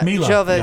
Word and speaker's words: Jovovich: It's Mila Jovovich. – Yeah, Mila Jovovich. – I said it wrong altogether.